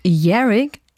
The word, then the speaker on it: Yerrick: Aber nein, sie schreibt auf ihrer Homepage, it's pronounced Yerrick as in Yarrow Wizard Harry, but with ick instead of a Wizard Harry.